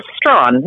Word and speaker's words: Strawn: Karen, now, did I say that right? Is it Strawhan? – It's Strawn. – Strawn. – It's like, okay. – You know, Stevie Ray Vaughan.